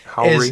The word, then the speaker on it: Howery: And then you have Lil Rel, that's how you say his name, Lil Rel Howery. – Lil Rel Howery. Howry.